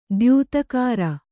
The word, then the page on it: ದ್ಯುತಿಗರೆ: kannada